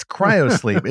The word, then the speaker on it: cryosleep: I want to say cyrosleep, gyrosleep. I don't know what it's. Zach, you're also hero sleep. That just doesn't work. It's cryosleep, is it not, Zach? That's the correct pronunciation.